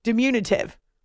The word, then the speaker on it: diminutive: It's about emphasizing the second syllable, diminutive.